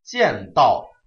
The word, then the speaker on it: 间道: 下一句见道归凤翔，见道注意它的读音啊，指的就是沿着小路偷偷摸摸的逃走，然后去了凤翔这个地方。